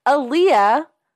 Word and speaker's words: Aaliyah: First famous adjacent is Aaliyah Koblenz. Pronounced like the singer, but not named after her.